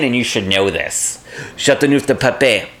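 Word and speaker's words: Châteauneuf-du-Pape: Ask a French person how to pronounce Chateauneuf du Pape. And they will say, Chateauneuf du Pape.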